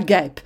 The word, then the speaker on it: guêpe: Donc, guêpe, ça s'écrit G-U-E, accent circonflexe, P-E. Guêpe. Alors, avec le E, on, on, a le G dur, mais pour avoir le G dur, on est venu insérer le U entre les deux lettres pour que le G se transforme en G dur.